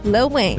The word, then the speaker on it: Lil Wayne: Can you say his name again? Because I think you put a T in there. – No, I said Lil Wayne.